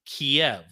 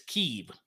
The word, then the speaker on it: Kyiv: Growing up and up until this whole encounter happened, I was brought up and taught that it's Kiev. Now all of a sudden it's Kyiv.